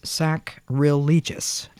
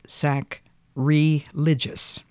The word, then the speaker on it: sacrilegious: The word is sacrilegious, not sacrilegious. It's spelled S-A-C-R-I-L-E-G-I-O-U-S. It comes from the word sacrilege, not from the word religious.